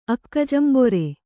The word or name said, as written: ಅಕ್ಕಜಂಬೊರೆ